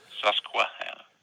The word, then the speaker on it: Susquehanna: Is it Susquehanna County? Is that, what's the pronunciation? – Yeah, Susquehanna.